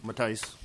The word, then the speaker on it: Mattice: I'm Mark Matisse. I'm with the Greater Minnesota Regional Park. – Could you say your last name again? – Matisse. – Matisse? – Yeah. All right, thank you. – M-A-T-T-I-C-E.